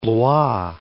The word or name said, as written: Blois